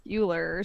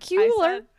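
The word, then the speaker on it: Cuellar: You said it like Euler or something. Like, you didn't say Ferris Bueller, but Kuehler. – Or you said like Sular or something. I said Kuehler.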